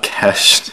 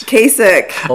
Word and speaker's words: Kasich: Jeb Bush, Peggy Skylar, John Kesh. – Kasich.